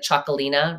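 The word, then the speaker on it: Txakoli: I mean, well, if you see like Chocolina, right, or Chocoli, it's spelled with a T and an X. So you probably wouldn't guess that by me saying Chocoli.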